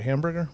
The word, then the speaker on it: Hamburger: Well, there's so much to unpack here, but let me just kind of work backwards and start first of all with Hamburger, the author, I'm saying that right, Hamburger, right? Mm-hmm.